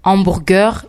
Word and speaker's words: hamburger: And then we have hamburger, which in French is hamburger. It's spelled the same way but pronounced differently. Hamburger, hamburger.